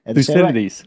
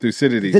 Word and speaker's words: Thucydides: And, you talk about the, see if I say it right, the Thucydides trap. – Thucydides. – Right? Thucydides. – Thucydides trap. – Yeah, Thucydides trap. It's a Greek guy, yeah.